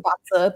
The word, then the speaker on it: BaZi: We pronounce it Baza, but it looks like Bazi.